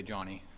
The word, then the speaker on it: Ioannis: The reason I say that is because John's actual name in Greek is Ioannis. It actually has the E on the end of it, like Johnny. So if you wanted to say it right, you'd probably say Johnny, just for the record.